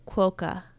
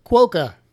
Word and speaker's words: quokka: It's called a quokka. – Quokka. Spell it. – Q-U-O-K-K-A. – Quokka. We think that's how you say it.